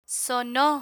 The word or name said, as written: sono